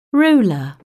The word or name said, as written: ruler